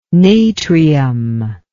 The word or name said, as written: natrium